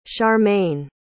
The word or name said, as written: sharmane